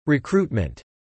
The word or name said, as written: recruitment